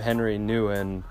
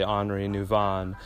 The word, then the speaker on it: Henri Nouwen: I'm going to pronounce his name Henry Nguyen, but I know it's, it's pronounced actually Henri Nouwen.